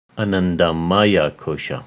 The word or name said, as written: Anandamaya-Kosha